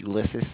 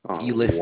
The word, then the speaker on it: Ulysses: So, my name is Ulysses, sir. It's ULYSESS. – Okay, Melissa. Okay. – No. – Ulysses.